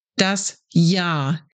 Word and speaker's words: Jahr: So, the R at the end is not pronounced. Das Jahr. But that doesn't mean that you pronounce the word as if there was no R at all.